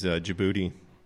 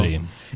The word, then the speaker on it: Jaboody: Yeah, my name is Djibouti. – Djibouti. – And how do you spell that? – J-A-B-O-O-D-Y. – J-A-B-O-O-D-Y. Wow. – Djibouti.